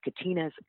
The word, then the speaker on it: Katina: and it's Katina's Classroom, and it's spelled a little differently because my name, Katina, is with a K.